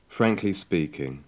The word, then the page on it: frankly speaking: English